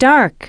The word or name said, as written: dark